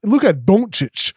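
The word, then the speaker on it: Dončić: Everybody mispronounces Luka Doncic. It's Luka Doncic. That's the way it should be pronounced.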